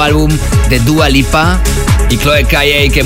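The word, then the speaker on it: Chloé Caillet: Aunque hemos escuchado la remezcla de la francesa Chloé Calle. Aunque se escribe Chloé Cayet, pero la pronunciación es correcta como la he dicho, eso sí que lo he chequeado.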